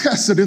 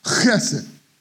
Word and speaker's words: chesed: Friends, oftentimes when you see those two words together, steadfast love, it's chesed in the Greek. H-E-S-E-D, chesed. I'm not clearing my throat, that's how it's pronounced in Hebrew.